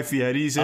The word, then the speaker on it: Fieri: Is that how you say his last name? – Fieri? It is, yeah, actually. Huh. That's how they say it in the movie.